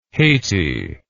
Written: Hayti

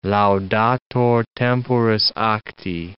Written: laudator temporis acti

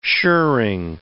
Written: shirring

